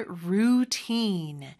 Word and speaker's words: routine: 그다음에 루틴이라는 단어가 모음 소리가 생각보다 길어요. routine 이렇게 하시면 돼요. u 발음 길게, teen 이 발음 길게 해주시면 되죠. I stick to my routine.